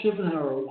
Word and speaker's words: Schuffenhauer: I have Bill Schuffenhauer. Is that how you say it? – Schuffenhauer. – I have Bill Schuffenhauer.